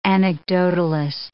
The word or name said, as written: anecdotalist